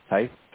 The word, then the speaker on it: Tysse: My name is James Tice. – Okay, can you spell that? – Sure. T-Y-S-S-E.